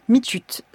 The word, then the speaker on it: mitiut: C'est-à-dire, ça se prononce vraiment M-I-T-I-U-T, mitut.